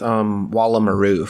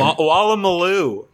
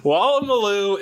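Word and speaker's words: Woolloomooloo: I'm going to go with Wallamaroo, final answer. – Wallamaloo, not Wallamaroo. – Yeah, that one. – Wallamaloo is correct.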